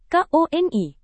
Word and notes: koni: indonesian

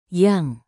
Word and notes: yang: indonesian

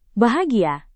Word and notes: bahagia: indonesian

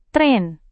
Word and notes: tren: indonesian

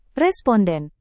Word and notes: responden: indonesian

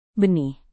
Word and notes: benih: indonesian

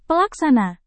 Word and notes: pelaksana: Indonesian

